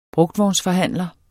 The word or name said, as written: brugtvognsforhandler